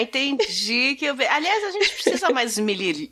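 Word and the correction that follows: esmerilhar: Como é que fala? – esmilhar Esmirilhar. Esmirilhar. Esmirilhar.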